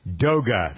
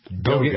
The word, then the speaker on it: DOGA: DOGA, though, that's the correct pronunciation, right? – DOGA. It is. That's right.